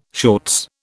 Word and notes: shorts: British English

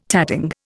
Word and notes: tatting: Indian

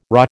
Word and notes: rah: American English

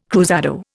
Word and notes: cruzado: Indian

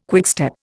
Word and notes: quickstep: Indian

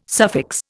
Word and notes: suffix: Indian

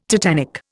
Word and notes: tetanic: Indian

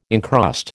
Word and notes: encrust: American English

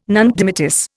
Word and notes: nunc dimittis: Indian

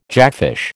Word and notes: jackfish: American English

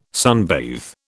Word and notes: sunbathe: British English

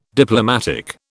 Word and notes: Diplomatic: British English

